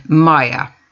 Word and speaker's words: Mia: And Mia can also be Maya.